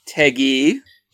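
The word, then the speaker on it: Tagge: Jerry Tague, is that how you say it? – Taggy.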